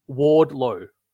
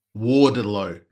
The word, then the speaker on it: Wardlow: Wallow. Is how you say it? – Wardlow. – Wardlow. Wardlow. Yeah.